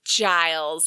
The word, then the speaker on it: Giles: Am I saying Giles or Giles? – Giles. With a soft G.